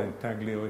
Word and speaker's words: intaglio: This is something I'd like you to remember very carefully, that engraving and intaglio, or intaglio if you wish to pronounce it like that, are not synonyms.